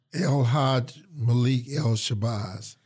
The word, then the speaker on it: El Hajj Malik El Shabazz: How do you say the full name? It's El Hajj? – El Hajj Malik El Shabazz.